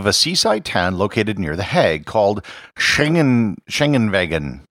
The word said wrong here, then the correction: Scheveningen: You know what? I'll just do this. – Scheveningen. – Germans, and pretty much everyone else on the earth for that matter, cannot pronounce the word properly.